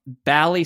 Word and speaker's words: Bally: First, Tony says, the first syllable of Bally Sports is pronounced like balance, not like the last syllable of baseball.